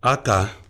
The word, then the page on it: akaaa: Efik